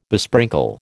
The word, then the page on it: besprinkle: American English